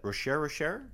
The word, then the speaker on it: Rocher: Ferrero Rocher. – That's wrong. – I feel like we could spend more time mispronouncing it back and forth, but. So let's do that. Rocher.